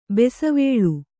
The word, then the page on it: ಬಸವಳಿ: basavaḷi